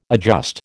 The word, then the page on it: adjust: American English